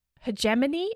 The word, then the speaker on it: hegemony: There's like this academic word that I can never pronounce right. Hegemony? Is that how you say it? – Yeah. Mm-hmm.